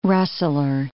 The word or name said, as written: rustler